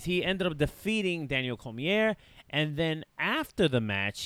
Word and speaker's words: Cormier: Ends up winning his match against Daniel Cormier. Did I say that right? – Yeah, oh, it's French. it's like Benoit.